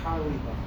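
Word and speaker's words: Carlebach: The name isn't Kalbach. – Rav Kalbach was the Rosh, was the Mashkiach. – The proper pronunciation of the name is Karlibach.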